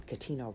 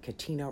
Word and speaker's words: Katina: I am on Instagram and I am on Twitter as just my first and last name, Katina Rankin. I am on Facebook at Katina Rankin Official, and I spell that with a K. It's K-A-T-I-N-A, and the last name Rankin, R-A-N-K-I-N.